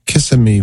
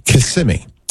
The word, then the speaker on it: Kissimmee: Eduardo is in Kissimmee, Florida. I said that wrong. Kissimmee. is how you pronounce that.